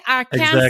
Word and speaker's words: Arkansas: It took me quite a bit to learn how to pronounce Arkansas. Yeah. Like, it's literally Arkansas.